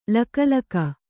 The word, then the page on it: ಲಕಲಕ: laka laka